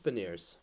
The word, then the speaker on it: Beniers: I always say it wrong. Beniers? Is that right? Is it Beniers? – I think it's just Beniers.